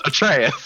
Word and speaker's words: Atreus: Like the band, Atreyu. It's pronounced Atreus.